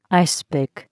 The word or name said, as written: ice pick